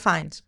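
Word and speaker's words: Fiennes: Ραλφ Φίνς, ο Βόλτεμορτ. – Ραλφ Φάινς. – Δεν μπορώ ποτέ να προφέρω το επιθετό του.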